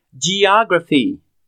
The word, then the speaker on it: geography: Geography. G-E-O-G-R-A-P-H-Y. A pronúncia é geography. Assim como em geometry, geometria, a mesma pronúncia no começo da palavra.